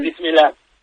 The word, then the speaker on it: Bismillah: Una sí, cosa que me dijo Iria Sacomag, a ver si lo pronuncio bien, Bismillah. – Eso es. – ¿Lo he dicho bien? – Bismillah. Sí, muy bien, muy bien.